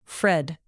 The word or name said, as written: fred